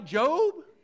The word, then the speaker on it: Job: The word is spelled J-O-B. How do you say that? Isn't it a job? Everywhere I've ever been, J-O-B is a job, so I'm reading about Job. Shouldn't he put an E on it if he's going to go by Job?